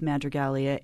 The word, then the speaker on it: Madrigalia: First of all, for listeners who aren't familiar with Madrigalia, it sounds like the word madrigal.